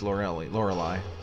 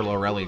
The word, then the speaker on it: Lorelei: Oh, he beats Lorelei. I always called her Lorelei growing up, but I've heard her name pronounced Lorelei, and I think that's more right.